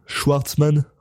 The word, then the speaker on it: Schwartzman: Schwartzman, ouais.